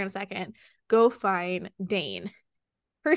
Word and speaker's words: Dain: That's how you say his name? Dain? – Yes, so or Dain? – Dain. Dain. So, yes.